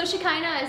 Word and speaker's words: Shekinah: Most people say China, so Shekinah is a big improvement.